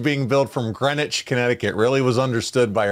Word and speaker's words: Greenwich: He put Greenwich, but yeah, Greenwich. There you go. – Yeah, well, it's spelled Greenwich. It is Greenwich.